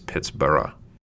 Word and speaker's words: Pittsburgh: originally pronounced by the largely Scotch Irish inhabitants Pittsburgh, like Edinburgh.